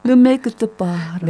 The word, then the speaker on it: Ne me quitte pas: 눈메키트 파.